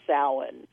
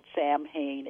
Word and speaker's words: Samhain: it kind of goes back to an ancient Celtic festival, and it looks like it's spelled Samhain. It's actually pronounced Samhain, despite all the movies that have characters called Samhain in them.